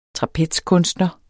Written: trapezkunstner

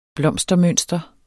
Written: blomstermønster

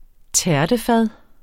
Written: tærtefad

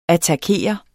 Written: attakere

